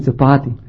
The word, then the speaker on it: copati: In spomnim se, da pred mojo glavo sta bila dva hišna čevla, kot neki hišni copati. – Yes. How do you say it? – Copati. – Copati, yes, copati.